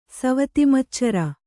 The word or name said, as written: ಸವತಿಮಚ್ಚರ